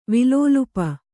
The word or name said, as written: ವಿಲೋಲುಪ